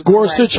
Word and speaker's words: Gorsuch: Well, a lot of people think it's going to be Gorsuch, I think I'm pronouncing. – Yes, Gorsuch. I've heard Gorsuch and also, what is it, Hardiman? – Right, that's the other person.